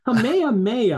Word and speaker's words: Kamehameha: Kamehameha, I think, is what it Kamehameha.